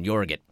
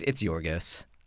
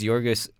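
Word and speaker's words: Yorgos: It's Jorgos. – But, I was like, maybe there's a different pronunciation that I'm not aware of. Jorgit. – Nope, it's Jorgos.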